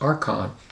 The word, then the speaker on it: archon: It's a Greek word pronounced archon, A-R-C-H-O-N.